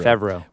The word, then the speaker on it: Favreau: How do you say that? Favreau. – Favreau.